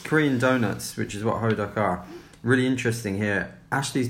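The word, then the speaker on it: Hoduck (호떡): which are donuts called Hoduck. They're not spelt Hoduck, but that's how it's pronounced.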